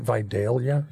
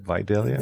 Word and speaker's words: Vidalia: They're in Vidalia, Georgia. Am I saying that right? Vidalia? – Vidalia. – Is that okay? – Vidalia. – That is how most people say it. The local people say Vidalia, but yeah.